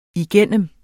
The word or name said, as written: igennem